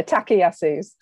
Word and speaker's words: Takayasu: And Artie also has a very rare vasculitis called Takayasu's. Did I pronounce that right? – You've pronounced it better than most people tend to. – I've been practising. – Yeah, Takayasu's.